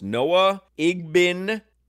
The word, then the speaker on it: Noah Igbinoghene: Your new Dolphins cornerback is pronounced Noah Igbinoghene.